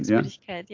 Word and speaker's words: Frühlingsmüdigkeit: And Lena told me about this Frühlingsmüdigkeit. Is that how you say it? – Yes, in German? Frühlingsmüdigkeit, yeah.